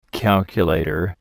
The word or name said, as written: calculator